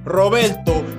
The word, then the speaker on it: Roberto: And for those of you who have trouble saying it that way, just like you have trouble saying my name is Roberto, and yes, you do got to roll the R's.